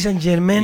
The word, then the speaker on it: Saint-Germain: Exacto. Saint-Germain. Pronuncie bien, querido.